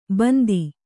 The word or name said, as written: ಬಂದಿ